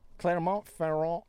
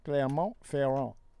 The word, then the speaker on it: Clermont-Ferrand: Clermont-Ferrand University Hospital in France. I worked on pronouncing that. – That's nice. – Clermont-Ferrand University Hospital in France announced that it is opening a wine bar for its terminally ill patients.